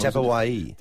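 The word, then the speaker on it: Tapuai: Or how was Clarkie pronouncing him last time? – Tapawai. – Is that Tapawai? – How you do it? – Yes.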